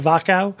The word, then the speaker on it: Wachau: It's spelled Wachau, W-C-H-A-U, I believe. They pronounce it Wachau.